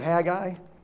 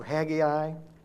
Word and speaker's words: Haggai: All right, let's turn to Haggai, or Haggai, as my son Austin told me I wasn't pronouncing it right.